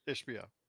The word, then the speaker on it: Ishbia: Ishbia.